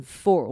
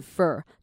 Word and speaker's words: for: 好，这边这个 for，我们念快的时候不会把它念成 for，我们念成 fur，就有点像 f e r 那个样子。